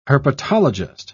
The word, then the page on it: herpetologist: British English